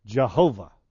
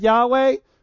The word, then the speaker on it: Jehovah: You've heard the word Jehovah. And it's the same consonants as Yahweh, but it has different vowels to it.